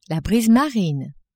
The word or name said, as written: brise marine